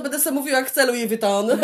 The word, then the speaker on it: Louis Vuitton: you all say it wrong, czyli wszyscy mówicie tak. źle. To się mówi Louis Vuitton,